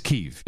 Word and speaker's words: Kyiv: And by the way, before you play, so people can keep their ear out, we've gotten three notes so far confirming that her pronunciation of Kiev is correct. – Yes. It's Kyiv, yes.